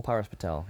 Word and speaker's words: Paras Patel: Paras Patel, yeah.